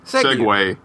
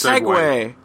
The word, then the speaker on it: segue: Segue. How do you say that? – Segway. – What? – It's Segway. – How many episodes have you been letting me say it wrong? – All of them.